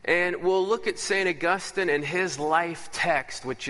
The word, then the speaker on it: Augustine: And let's just get his name right first as we kick it off. Saint Augustine is a place. Augustine is a man.